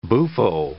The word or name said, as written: buffo